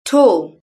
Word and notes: tall: British English

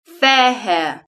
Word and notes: fair hair: British English

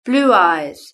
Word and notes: blue eyes: British English

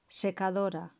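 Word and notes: secadora: Spanish (Spain)

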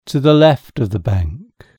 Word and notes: to the left of the bank: British English

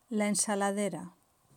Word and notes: la ensaladera: Spain

